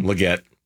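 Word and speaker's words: Legette: Did I pronounce the name wrong? – Leggett. – Yeah, okay, Xavier. Close enough. I went with the French version.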